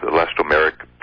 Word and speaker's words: elastomeric: One has to do with resurfacing stucco, and the other has to do with elastomeric paint, if that's how you pronounce it properly or not.